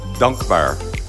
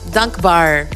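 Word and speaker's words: Dank Bar: Dunk Bar? It's like a longer A. Dunk Bar. – Dunk Bar. – Bar. – Bar. – Yeah. – Dunk Bar. – You know, my Dutch pronunciation is not perfect either, but that's, yeah, it means grateful, thankful, because I got through COVID and feeling like I want to do this again.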